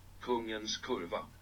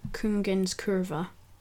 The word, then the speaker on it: Kungens Kurva: Kungens Kurva. – That's apparently how you pronounce the name of that. Kungens Kurva.